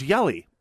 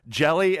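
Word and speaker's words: Hjelle: And you can guess that they were both pronounced differently. Oh yeah, one of them was Jellum, and the other one was Yelly and they're both different corruptions from the original Scandinavian, uh, but they had their preferred corruption.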